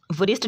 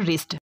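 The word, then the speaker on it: wrist: वरिस्ट नहीं पढ़ते, ये पढ़ते हैं रिस्ट।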